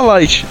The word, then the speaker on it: Light: Tipo, então, Raito é tipo a pronúncia japonesa, entendeu? Só que a gente é BR, porra, a gente fala Light.